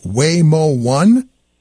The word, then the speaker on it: Waymo One: And there's something, what really caught my eye is something called Waymo One. Is that how you pronounce it? – And exactly.